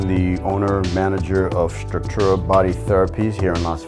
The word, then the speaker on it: Structura: Am I pronouncing it right by any chance? – Structura, you got it. – Structura, okay, perfect.